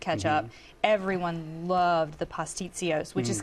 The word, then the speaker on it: pastitsios: And so it comes with, I hope I say this right, pastitsios.